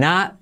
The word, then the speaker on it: not: I'm not, y esa T la pongo entre paréntesis porque casi no se pronuncia.